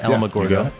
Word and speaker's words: Alamogordo: Alamogordo.